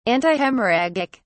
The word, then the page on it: antihemorrhagic: British English